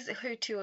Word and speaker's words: Hutu: Because I'm not sure whether you're Hutu or Tutsi. Am I saying it right? – It is Hutu or Tutsi.